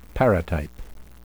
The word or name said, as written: paratype